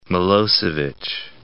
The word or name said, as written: Miloaevic